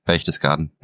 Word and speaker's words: Berchtesgaden: I didn't see it in Berchtesgaden. Am I saying that right? – Yeah, Berchtesgaden. – Berchtesgaden.